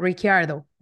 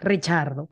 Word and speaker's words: Ricciardo: Pero creciendo en Australia, pues es mucho más fácil como decir como Ricciardo, ¿no? O sea, como que es un, es la forma en inglés como más fácil de pronunciarlo. Entonces, pues si me presento, yo digo Daniel Ricciardo por, por facilidad, pero la forma correcta, real, pues sí es Richardo, porque italiano, ¿no?